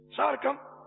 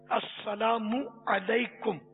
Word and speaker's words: السلام علیکم: بہت تھوڑے ہیں تلفظ جن کا صحیح ہو، بہت تھوڑے۔ السلام علیکم، السلام علیکم، سارکم، ہاں، اس طرح بس طرح طرح کی آوازیں نکالتے ہیں، حالانکہ عربی میں یہ سلام ہے السلام علیکم،